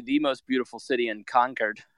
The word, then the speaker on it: Concord: Concord. – It's, I was informed at the podcast taping today that is how you say it. It is not Concord. Like, you know, a true Englishman should say Concord.